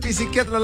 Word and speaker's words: psiquiatra: Mira, no se dice psiquiatra, la P no se pronuncia.